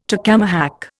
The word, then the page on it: tacamahac: Indian